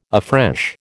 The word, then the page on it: afresh: American English